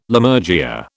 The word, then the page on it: Lammergeyer: British English